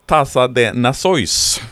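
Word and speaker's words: Taça das Nações: men det heter alltså Tassa de Nasois. – Är det så det ska uttalas? – Tassa de Nasois, det, det är portugisiska.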